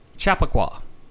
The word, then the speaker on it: Chappaqua: Chappaqua? – Chappaqua.